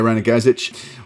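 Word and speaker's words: Ranogajec: like David Walsh or his partner Zeljko Ranogajic. I'm not pronouncing that very well. – Yeah, Ranogajic.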